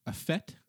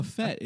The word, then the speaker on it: fete: I learned what a FET, I believe it's pronounced, is.